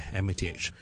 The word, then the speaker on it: math: 佢话数学 math math 同埋 maths maths 有咩分别咧？mathematics 啦，我哋叫做美国咧简称咧，我哋叫 math 嘅 math。 I do math at college, for example, math。咁但系英国咧通常叫 maths 嘅 maths 有 s 嘅 maths maths。順便講一講佢發音有啲難嘅，咪 math math，ok th 音脷尖縫喺上面嗰排門牙嘅下方，輕輕出氣 math，跟住我線翻入去 s 音個位，嘶嘶沙沙嗰個位 math 脷尖線入去，中間唔使停頓 maths maths maths，ok 咁啊，俾個例句大家啦，I used to love maths，which is true。